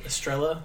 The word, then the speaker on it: Estrella: Am I saying her name right? – Sure. – Okay. – Estrella? – Estrella.